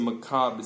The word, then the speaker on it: macabre: 이 말은 그 마지막 re 묵음입니다. 그래서 그냥 macabre이라고 하시면 돼요. 무시무시한, 아주 무서운, 끔찍한. Okay, it was a macabre scene이라고 하시면 되고 masquerade. mask에서 왔어요. M-A-S-K.